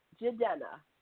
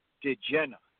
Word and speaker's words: Jidenna: J-I-D-E-N-N-A, Jedenna. – Jedenna. Jedenna. It starts with a J? – Yeah, it starts with a J-I and Denna. J-I-Denna.